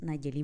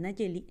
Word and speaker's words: Nayeli: Porque aquí las personas no pueden pronunciar como tal Nayeli, entonces me decía Neleli, Negeli, Nele, Nelly. Entonces, bueno, algunos hasta incluso me llegaron a decir, te puedo decir Nela, Nelo, Nelly, y yo les dije, perfecto, está bien, Nelly.